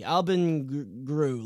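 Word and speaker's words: Albin Grewe: but Albin Greve, G-R-E-W-E, Albin Gru, let's call him, is someone who absolutely dominated the Swedish Junior League.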